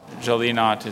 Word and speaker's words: gelinotte: Jolly Knot, G-E-L-E-N-O-T-T-E, Jolly Knot. – We have an elimination. – Jolly Knot is spelled G-E-L-I-N-O-T-T-E.